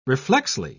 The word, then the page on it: reflexly: British English